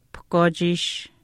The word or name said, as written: pahkwaachiish